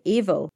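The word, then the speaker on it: Aoibheall: C-L-I, Fada, Fada's little accent, C-L-I for the O-N-A. And if you're an O'Brien, your banshee is called Evil. So that's A-O-I-B-H-E-A-L-L. And it sounds like the word evil, but evil.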